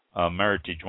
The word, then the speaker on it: Meritage: And shouldn't be pronounced as if it were French, because it's not a French name. It's Meritage.